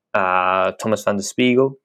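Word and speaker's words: Tomas Van Den Spiegel: But wrapping this up, I want to give a big shout out and a congrats to Thomas van der Spiegel. Is that if that's how you pronounce his name correctly? – Yes.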